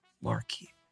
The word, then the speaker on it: Marchese: That months later, when talking to the SID and he just mentioned Marchese, and I'm like, Marquise. Who's wait, that? Is that how you say it? He's like, yeah.